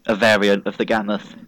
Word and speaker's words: Gammoth: I assumed you were just saying it properly, like, even though you're saying Glammoth and I'm just hearing Gammoth like every time.